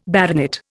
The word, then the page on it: baronet: Indian